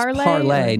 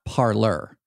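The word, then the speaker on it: Parler: I believe it's pronounced Parlay, not Parlor. So I think it's as Ramesh Panuru tweeted out, I prefer my populist alternatives to Twitter to not have French sounding names.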